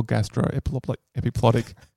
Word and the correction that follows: gastroepiploic: It was worth it just for the mispronunciation of gastroepiploic.